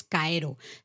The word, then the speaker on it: kaeru: Y hemos dicho, claro, rana significa, bueno, se dice en japonés Kaeru. Se escribe con un kanji, con un ideograma concreto, ¿vale? Pero se lee Kaeru.